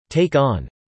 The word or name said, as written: take on